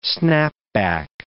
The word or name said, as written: snapback